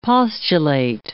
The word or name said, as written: postulate